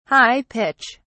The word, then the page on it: high pitch: British English